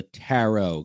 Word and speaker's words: tarot: you pronounce it like a parrot, but it's a tarot card.